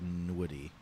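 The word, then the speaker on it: Nwude: It's N-W-U-D-E, so let's just go with Nweedy. – Nweedy? – Annuity.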